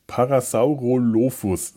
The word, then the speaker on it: Parasaurolophus: Parasaulophus. Nein, Parasau, Parasaurolophus.